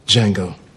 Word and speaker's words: Django: Django. – Can you spell it? – D-J-A-N-G-O. The D is silent.